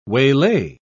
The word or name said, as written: waylay